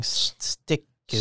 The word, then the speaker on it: Styku: It's I don't know how to pronounce it. S T Y K U. Yes. Body scanning. stick you. – Stick you.